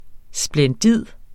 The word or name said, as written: SPLENDIDE